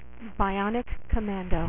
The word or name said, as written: bionic commando